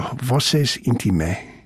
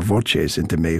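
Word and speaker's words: Voces intimae: This quartet, Opus 56, bears a title, Voces Intime, or Voces Intime, if you prefer the Italian pronunciation.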